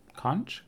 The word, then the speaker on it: conch: It's in this, like, giant shell, this giant, like, conch shell. Conch? Conch? A giant shell. – Both of them work for me. I'm good with either pronunciation.